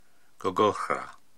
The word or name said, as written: Gogohra